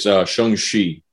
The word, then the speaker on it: Shang-Chi: It's Shang-Chi. That's how you say it.